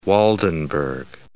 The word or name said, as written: Waldenburg